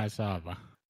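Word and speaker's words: Maezawa: And more interestingly, slightly linked to Starship, we had some big news on the Dear Moon from, I'm going to attempt his name, Yusaku Masuwa. Butchering it there. – Masaba. – There we go.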